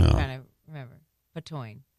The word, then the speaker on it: Patoine: Is it Pantoine? P-A-T-O-I-N-E? Is it Pantone? – P-A-T-O-I-N-E. Patoine.